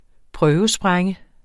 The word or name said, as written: prøvesprænge